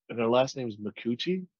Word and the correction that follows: Micucci: I believe. I actually really like her. I think it's Martha. Is it Martha Makuchi? Oh, it's, here you go. Kate Micucci. And I probably butchered her last name because apparently I can't talk.